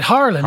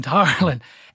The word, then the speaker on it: Harlan: Harlan, it is. I was going to say Harlan.